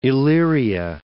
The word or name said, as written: Illyria